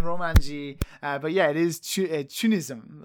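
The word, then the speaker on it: CHUNITHM: Yeah, so the katakana, yeah, is Chunism, which I imagine is just the Japanese way.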